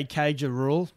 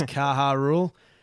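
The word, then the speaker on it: Caja Rural: I really want to say Cage of Rule, but I know it's Kaha Rule, dash Seguros RGA.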